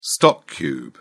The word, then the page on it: stock cube: British English